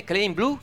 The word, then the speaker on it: Klein Blue: Come si pronuncia? Bene, Claim Blue, è giusto così? – Sì, sì.